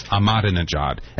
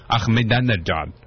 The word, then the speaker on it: Ahmadinejad: When this guy's name first started showing up in the paper last year, they put a pronunciation and they said it's Ahmadinejad. And nobody pronounces it that way anymore except me, and I'm just going to stick with that. So, I mean, no insult to you, President of Iran, but it's Ahmadinejad for me.